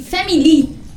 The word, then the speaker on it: Family: I Family, con l'accento sulla I finale.